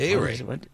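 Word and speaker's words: Ehire: His first name, E-H-I-R-E. I can't pronounce it. – A-Ray. Just think A-Ray.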